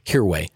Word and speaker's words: Hirway: Last name is Hirway. – Hirway. Oh, so the H is not silent.